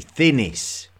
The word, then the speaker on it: Thinis: It's actually Thinis. Thinis is the lost capital city of early Egypt. It's spelled T-H-I-N-I-S.